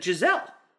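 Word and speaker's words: Gisele: I just said it wrong. I just called her a Gazelle instead of Giselle.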